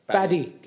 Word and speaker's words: Paddy: And we also have some really controversial topics to discuss, like Conor McGregor, Jake Paul, and Adam the Baddy Pimblet. – Paddy. – Pimblet. – Paddy the Pimblet. – Pimblet. My apologies for the mispronunciation.